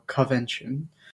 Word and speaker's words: Covention: But so Gus and Willow show up at the stands to let him know that it's because of the Coven Convention, or Covention, which is a subtle pronunciation that I'm going to say wrong every single time, so get ready for that.